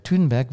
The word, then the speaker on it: Thunberg: Und als ich ein Beispiel gebracht habe, dass zum Beispiel ein Mensch unglaublich polarisiert, da ist mir sofort die Greta Thunberg oder Thunberg, wie sie ausgesprochen wird, eingefallen.